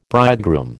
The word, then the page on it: bridegroom: American English